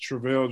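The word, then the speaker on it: Travele: Just Travail Judine. So my first name is spelled like the word travel. After the L, put an E.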